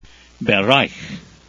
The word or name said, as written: bereich